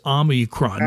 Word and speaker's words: Omicron: I've now been hearing that they have, especially with the developments over the last week with Omicron, however we pronounce it, it's there's no N in Omicron. It's Omicron. No N.